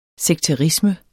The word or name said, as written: sekterisme